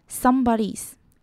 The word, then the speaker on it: somebody's: Somebody 我们要注意到的是字尾的 dy 不要发音发得太重，只要轻轻带过就好，还有后面的 s 也不能够省略， somebody's。